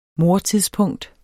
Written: mordtidspunkt

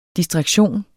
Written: distraktion

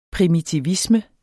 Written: primitivisme

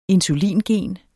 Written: insulingen

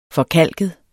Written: forkalket

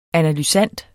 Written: ANALYSANDER

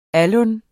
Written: alun

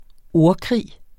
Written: ordkrig

